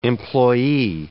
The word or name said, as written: employee